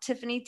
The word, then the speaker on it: Tiffanie: My name is spelled Tiffany, T-I-F-F-A-N-I-E, Kellogg, K-E-L-L-O-G.